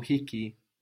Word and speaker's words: hiki: Hiki kitabu, kitabu hiki. Good. And be careful to pronounce that H. No? Hiki.